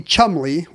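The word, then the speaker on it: Cholmondeley: The guy who called, Phillips Carlin, who called the 1920s World Series, reported that at his station, the announcers had to say Penelope Chumley, which is spelled Chalmondeley. It's a trap. You have to know it's Chumley.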